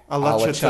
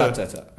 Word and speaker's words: Alaçatı: We're in Alaçatı. – Alaçatı.